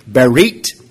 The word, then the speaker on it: berit: Berit. If you don't know Hebrew, the English transliteration there, B-E-R-I-T, pronounced Berit.